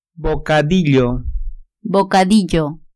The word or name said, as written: bocadillo